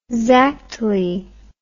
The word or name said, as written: zactly